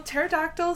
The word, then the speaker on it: pterodactyl: Okay, first of all, but for all my uneducated dino people, pterodactyl starts with the letter P, not T. Learned that today. – You learned that today? – Yeah. – Oh, no. – So silent P coming in there.